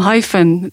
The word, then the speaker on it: Hyphen: Mijn eigen naam Hyphen, dat spreek je eigenlijk uit als het Engelse woord hyphen, wat een koppelteken is.